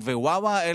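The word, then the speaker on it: וואווה (华华): איך מבטאים את השמות? אז בוא נגיד את זה. זונג זונג, לראשונה קוראים זונג זונג, לשנייה וואווה, שזה, אם מחברים זונג וואה, זה ביטוי לסינים בסינית.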